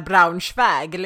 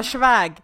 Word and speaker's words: Braunschweig: Såklart uttalar Paulina Braunschweig liksom. Det är inte en jävla schväg.